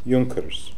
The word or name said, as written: Junkers